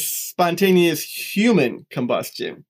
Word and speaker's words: Spontaneous Human Combustion: It's more about, you got to put the emphasis on the right syllable, right? It's Spontaneous Human Combustion.